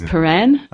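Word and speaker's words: Prahran: Actually, the pronunciation of that word is Praran.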